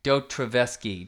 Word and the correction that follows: Dostoevsky: Travesky, Doy Doy Dostoevsky. – Dostoevsky. – Yeah.